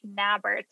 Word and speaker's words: Nabor: So my Instagram is Neighborhood Realtor, but it's spelled like my last name, which is not spelled like neighbor at all. it's spelled more like Nabor, N-A-B-O-R, and then the word hood.